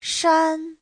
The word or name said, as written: shan1